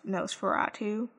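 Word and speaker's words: NOS4A2: It's Nosferatu, N-O-S, the number 4, the letter A, and then the number 2, but it is pronounced Nosferatu.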